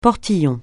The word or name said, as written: portillon